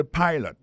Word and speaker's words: pilot: Pilot là, c'est même mot pilote là, il dit Uadeli, il écrit P-I-L-O-T. Alors, il s'est prononcé I dans le cas ça. Alors, et puis, dans le mot pilot là, tout, après accent tombé sur le premier syllabe là.